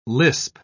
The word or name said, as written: lisp